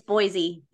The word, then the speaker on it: Boise: Have I said that right? I can never say that correctly. Boise. – It's Boise. Yeah. Just think of the most not French way to say something. And that's how the Americans say it most of the time.